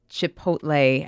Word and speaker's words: Chipotle: At the bottom of the ad, it actually says it is pronounced Chipotle, and it divides it into syllables, and it says L-A-Y.